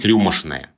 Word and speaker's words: рюмочное: Но ведь рюмочное или даже рюмы, э, мне кажется, это надо произносить рюмошное. – Рюмошное, да. Именно с таким налетом, да.